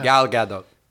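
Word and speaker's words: Gadot: Oh, you pronounce the T? – So, like, very briefly do it. I saw her on a show and she goes, Godot. Godot. Gal Godot. Right.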